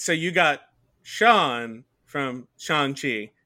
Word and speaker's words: Shang-Chi: but this is Shang-Chi. – You got it. – Okay. Yeah. Marvel's new movie. – Shang-Chi.